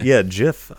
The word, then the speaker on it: Jif: Like is it pronounced GIF or spreads... Jif? – Piss off. Piss off. There's a J in there. Jif.